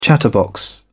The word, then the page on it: chatterbox: British English